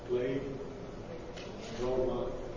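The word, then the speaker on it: Play Roma (πλήρωμα): You can see Pleroma there. Pleroma there. Can't reach that high. Well, I've got a pointer. Can you see it? P-L-E. Play. Roma. Play Roma, that means complete or fulfilled.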